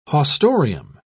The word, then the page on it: haustorium: British English